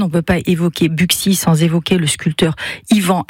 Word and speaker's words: Buxy: Buxy serait le seul nom de commune situé dans l'ancienne province bourguignonne, comportant un X au milieu, hein, où ce X se prononce X.